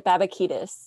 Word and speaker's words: Babakitis: Yeah, it's Greek, actually. You pronounce it Babakidis.